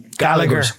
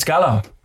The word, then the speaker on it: Gallagher: Did you ever hear the English pronounce an Irish name? So that fucking wrecks me head. You know what I mean? Like, who's the Oasis brothers? – Gallagher. – It's Gallagher.